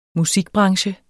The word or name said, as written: musikbranche